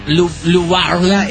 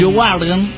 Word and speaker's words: Leeuwarden: Leeuwarden. – Él lo pronuncia porque él conoce toda esa zona. De pequeño estuvo por ahí jugando con. – Leeuwarden.